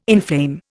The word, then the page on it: inflame: Indian